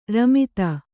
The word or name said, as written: ರಮಿತ